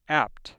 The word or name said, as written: apt